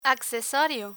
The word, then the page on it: accesorio: Spanish